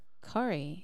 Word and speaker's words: Cori: C-O-R-I. – That's a dope name. – Ah. Yeah. – Corey. – Pronounce that Corey.